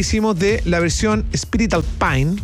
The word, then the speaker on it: Alpine: pero lo dicen mal porque la pronunciación real es más Alpine. No como Alpine. – Claro. – No, Alpine, no es anglo. – No, es francés, po.